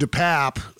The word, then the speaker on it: DePap: And this guy, David DePap, was... – Or DePap, as I'm hearing it pronounced now as well.